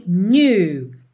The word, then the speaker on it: knew: E lá na primeira linha, the night we met, I knew. Knew. O K é mudo, tá? E esse NEW, ele não é assim muito desenhado new, new, tá? É new, new. It's a little French.